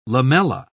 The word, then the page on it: lamella: British English